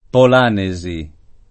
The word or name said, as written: Polanesi